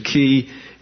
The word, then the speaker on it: chi: We call it a chi usually, but the correct pronunciation is a key, and is transliterated CH and has a K sound when spoken.